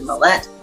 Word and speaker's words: Mallett: My name, Rose Mallett, I pronounce it Mallett, .com.